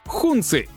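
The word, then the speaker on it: Hongqi: Модель называется HQ9, а марка произносится как «Хунцы». Если это название русское ухо смущает, то можете использовать его перевод — «Красное знамя».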